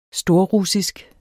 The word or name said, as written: storrussisk